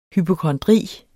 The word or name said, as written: hypokondri